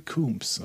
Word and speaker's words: Combs: Ja, ich meine, äh, Tomb Raider wird ja auch so ausgesprochen, ne? – Stimmt. Man wird gleich geschrieben bis Coombs. auf dieses T. Jeffrey Coombs, okay.